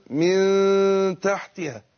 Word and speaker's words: من تحتها: أنا نطقت غنة هو بين الميم والتاء غنة مش نون. أقول ميتة من تحتها كده.